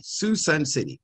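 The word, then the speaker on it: Suisun City: You got to say that right, Susun City, S-U-I-S-U-N City.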